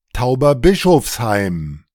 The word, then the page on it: Tauberbischofsheim: ta‿ubɐˈbɪʃɔfsha‿im; ドイツ語